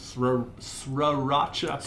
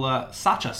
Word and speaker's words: Sriracha: This is Sriracha. – Sriracha? – Sriracha. – Sriracha. The first R is silent.